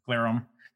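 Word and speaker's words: Glerum: There's a great story from Jay Garalum. I always pronounce his last name improperly. – Glarum.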